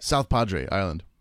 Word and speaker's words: South Padre Island: South Padre Island.